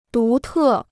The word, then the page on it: 独特: dú tè